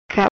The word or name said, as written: Cap